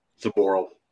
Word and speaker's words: Zaboral: Jacob Zaboral. – Zaboral. – Thank you. Damn it. Anyway, I put the emphasis on the wrong syllable.